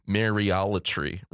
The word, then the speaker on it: Mariolatry: And so I come from that, you know, but even hearing the word Mariolatry, right, which, you know, it rhymes with idolatry, you know, is sort of what's being gotten at with that.